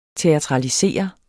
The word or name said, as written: teatralisere